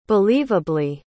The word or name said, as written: believably